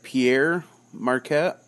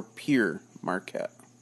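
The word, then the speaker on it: Pere Marquette: You hear it a couple different ways. You either hear it Pierre Marquette or Pier Marquette. I've heard it both ways. It's all the same. – And the Canadians pronounce it differently. – Yes. The Canadians definitely is Pierre.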